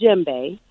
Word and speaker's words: Djembe: It's spelled a little weird, so I know it can come off like, how do you pronounce this? But the name of the drum is the name of the show. So it's called Djembe.